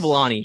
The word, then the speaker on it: Vellani: Vellani, yep.